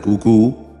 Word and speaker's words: QQ: El primer sistema recibió el nombre de QQ, pronunciado tal cual está escrito, con acento en la segunda U.